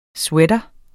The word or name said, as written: sweater